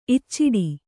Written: ಇಚ್ಚಟ

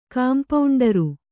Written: ಕಾಂಪೌಂಡರು

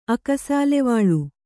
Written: ಅಕಸಾಲೆವಾಳು

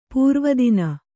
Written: ಪೂರ್ವದಿನ

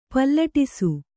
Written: ಪಲ್ಲಟಿಸು

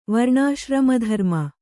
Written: ವರ್ಣಾಶ್ರಮಧರ್ಮ

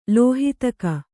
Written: ಲೋಹಿತಕ

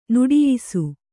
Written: ನಿಟ್ಟಯಿಸು

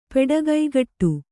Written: ಪೆಡಗೈಗಟ್ಟು

